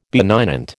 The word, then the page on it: benignant: American English